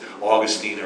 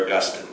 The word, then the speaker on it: Augustine: How you pronounce it depends on whether you want the French or the Spanish pronunciation, right? This Augustine or Augustine.